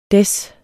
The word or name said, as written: Des